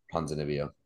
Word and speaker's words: Ponzinibbio: I hope this fight happens because I love Kevin Holland, and I think Ponzinibbio, is that how you say it? – Yeah, Ponzinibbio.